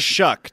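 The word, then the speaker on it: Shough: Also, Tyler – how do you pronounce the kid's last name? – Shuck. Tyler Shuck.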